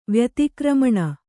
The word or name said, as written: ವ್ಯತಿಕ್ರಮಣ